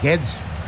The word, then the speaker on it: Guedes: Es Guedes. – Gonzalo Guedes. Parecía alemán, más que portugués.